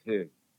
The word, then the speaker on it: hygge: I've been discouraged from speaking Danish, but it sounds something like hygge. – Mm-hmm. – H-Y-G-G-E.